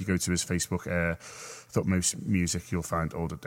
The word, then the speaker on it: Thutmose: Yeah, I think it's pronounced Thutmose. I've spelt it wrong, which doesn't help. Just spell it now for everyone. – People love it. – T-H-U-T. – Play the hits. – M-O-S-E.